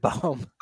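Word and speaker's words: Bohm: Did I say Boehm or Baum? – You said Baum. – Baum. – You've Blake, never said his name right first try. – And I've backed it up on Baseball Reference, which isn't always correct, but it does phonetically get spelled B-O-W-M.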